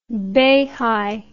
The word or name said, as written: beihai